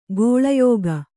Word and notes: ಕೌಳೇಯಕ: kauḷēyaka

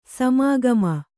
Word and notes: ಸಮಾಗಮ: samĀgama

